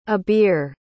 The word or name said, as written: abir